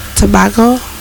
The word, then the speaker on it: Tobago: Tobago.